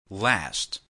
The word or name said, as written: last